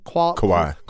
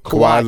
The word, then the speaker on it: Kawhi: Kawhi. Kawhi Leonard.